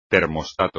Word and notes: termostato: espagnol

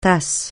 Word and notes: tasse: francais

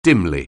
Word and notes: dimly: anglais

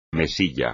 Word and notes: mesilla: Spanish

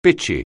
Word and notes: bitchy: anglais

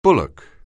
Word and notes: bullock: anglais

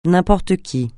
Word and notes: n'importe qui: francais

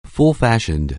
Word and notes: full-fashioned: anglais

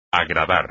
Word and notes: agradar: espagnol

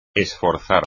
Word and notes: esforzar: espagnol

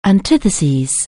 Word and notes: antithesis: anglais

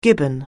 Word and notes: gibbon: anglais

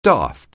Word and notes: daft: anglais

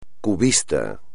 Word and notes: cubista: espagnol

